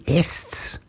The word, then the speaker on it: Estes: Now, for years I've agonized about how you pronounce his name. I thought it might be Estes, I thought it could be Estes, but apparently the preferred pronunciation for him was Estes.